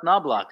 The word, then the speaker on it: Knobloch: Ah, Chuck Knobloch.